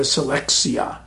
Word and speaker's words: selectia: every few weeks the commandant would declare a selectia. Selectia sounds like the word selection.